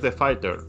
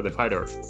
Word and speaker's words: The Fighter: La otra que traemos es The Fighter. O como dirían aquí en Nueva York, The Fighter. Ellos no pronuncian la T.